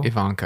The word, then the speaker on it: Evancho: Did you say Jackie Evancho? – Ivanko. – I don't know how to pronounce her name.